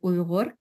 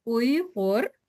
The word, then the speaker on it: Uyghur: I actually have a mini tutorial kind of went viral on Twitter about teaching people how to pronounce the word Uyghur correctly. So I can do a little tutorial now. It's Uyghur and it's that Uyghur.